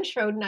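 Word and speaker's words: Sjödén: Don't spell it, but it's Gundrun Schwoden. I think it's S-J-O-D-E-N for anybody.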